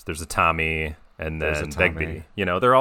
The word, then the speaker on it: Begbie: Yeah, Robert Carlyle as Bigby. Begbie.